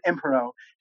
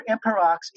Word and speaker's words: Emperox: So the way that I've sliced this baby in half, Solomon-like, is to say the emperor and the court pronounces it impero. However, Emperox is recognized as a valid regional variant. So if you are pronouncing it Emperox, congratulations, you're from the independence equivalent of the North, yeah.